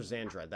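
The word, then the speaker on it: Xandred: Oh yeah, Master Zandred. That's how you pronounce it.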